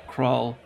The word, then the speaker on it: Krull: I always pronounced it Krull, but I guess it's correctly pronounced Krull.